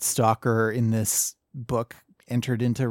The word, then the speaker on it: S.T.A.L.K.E.R.: There is a film in 1979 called Stalker that the brothers wrote the screenplay for. – Yeah. – There is a series of games called, let me make sure I'm pronouncing this right, S-T-A-L-K-E-R.